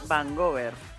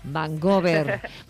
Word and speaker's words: Vancouver: Agoney se llama Vancouver, o ¿cómo lo dices tú? ¿Cómo lo pronuncias? – Así mismo es, Vancouver. – Vancouver.